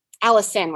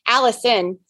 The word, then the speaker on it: allicin: So this compound, alanine, or wait a minute, did I say that right? Allicin, why am I saying that? Allicin, the sulfur-containing compound, is 7 times higher in black garlic.